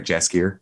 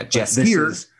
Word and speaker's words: Jaskier: Is it Jaskier or Jaskier? – Soft J. – It's J, right? Jaskier? – Mm-hmm. I think it's Jaskier. – Yeah, Jaskier.